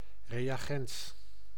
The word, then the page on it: reagent: Canada; /ɹi.ˈeɪ.dʒɛnt/